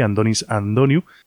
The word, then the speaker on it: Antonis Antoniou: Y ahora Andonis Antoniou, escrito Antonis Antoniou, aunque parece ser que se pronuncia más bien así, Andonis Antoniou, nos avanza lo que va a ser su presentación en solitario.